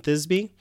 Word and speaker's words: Thisbe: Yeah, there's also an element in this story of an ancient story called Pyra- well, I'm going to say that maybe I'm going to say this wrong because I've only ever read it. Pyramus and Thisbe. – That's how I've always heard it.